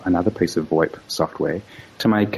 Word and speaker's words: VoIP: Sometimes it's referred to by the phrase Voice Over Internet Protocol, which is given the acronym VOIP, sometimes pronounced VoIP.